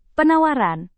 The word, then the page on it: penawaran: Indonesian